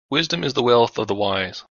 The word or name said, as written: Wisdom